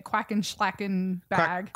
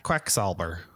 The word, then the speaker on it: Quacksalber: I can't pronounce it very well, and I just kept calling it Quacken Schlacken Bag. – Quacksalber. – Is it Die Quacksalber von Quedlinburg? – Yeah, sure. – So it's Quacksalber.